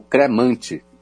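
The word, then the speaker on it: Crémant: A gente tem também os Cremant, ou cremante, C-R-E-M-A-N-T, Cremant. O Cremant são vinhos com denominação de origem. A gente tem o Cremant d'Alsace, feito na Alsácia, Cremant de Bourgogne, feito na Borgonha, e o Cremant do Loire, feito no Vale do Loire.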